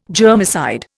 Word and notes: germicide: Indian

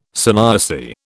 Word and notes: sanyasi: British English

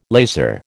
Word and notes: lazar: American English

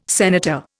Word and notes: senator: Indian

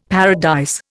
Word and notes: paradise: Indian

